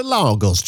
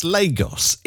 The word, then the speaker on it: Lagos: It's you said into Lagos, Jono, with an R after the A. Well, it's Lagos.